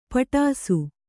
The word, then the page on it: ಪೆಡಸು: Kannada